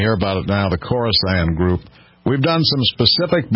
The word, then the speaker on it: Khorasan: I've pronounced that 14 different ways this week. K-H-O-R-A-S-A-N, Khorasan.